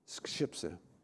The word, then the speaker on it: skrzypce: which is skrzypce."